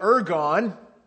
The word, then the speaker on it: ergon: The word works there in English is from a Greek word. You pronounce it ergon.